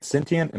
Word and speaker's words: sentient: The word sentient, am I saying that right? – Yep.